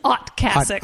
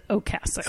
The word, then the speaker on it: Ocasek: Like, I thought there's no way that Rick, is it Ocasek or Ocasek? – Well, what's so funny is I know when Matt was telling us that he pronounced it Ocasek, his real name is Otkasek. – Otkasek. – So, you know what, he can claim whatever. But I think he pronounces it Ocasek.